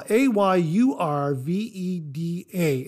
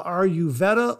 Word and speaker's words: Ayurveda: Now, uh, Ayurveda is spelled uh, A Y uh, A Y U R V E D A, and it's pronounced either uh, Ayurveda or Ayurveda. I've heard it pronounced both ways.